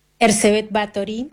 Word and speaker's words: Erzsébet Báthory: Erzsébet Báthory, lo pronunciaste muy bien.